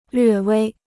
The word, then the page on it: 略微: llüè wēi